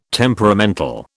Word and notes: temperamental: British English

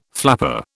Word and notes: flapper: British English

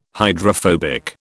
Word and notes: hydrophobic: British English

